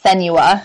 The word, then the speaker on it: Senua: Like, that was not like Ninja Theory got creative, not only with the premise of that game, but with Sienna, sweet, I can never say her name right. – Oh, yeah, it's kind of a weird one. It's Senua.